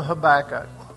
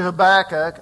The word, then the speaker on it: Habakkuk: I grew up calling him Habakkuk.